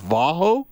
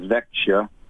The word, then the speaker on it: Växjö: Because I always thought it was Vaho, and then I'm told, no, it's Vekho. And then I'm told from people that different parts of Sweden pronounce the name of the team differently. So how do you pronounce the team that Joel Pearson plays for? – Well, the easiest way when you're kind of talking in English is Växjö.